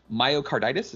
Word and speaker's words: myocarditis: Myocarditis, is that right? – Yep, that is correct.